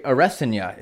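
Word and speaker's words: Adesanya: I've been hearing that he prefers now to go by Arasanya is the proper pronunciation.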